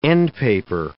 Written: endpaper

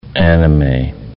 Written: anime

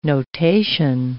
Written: notation

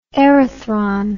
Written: erythron

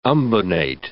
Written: umbonate

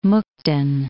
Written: Mukden

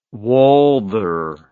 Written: Walther

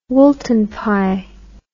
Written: woolton pie

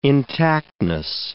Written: intactness

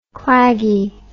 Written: claggy

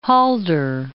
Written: Halder

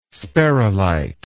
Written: sperrylite

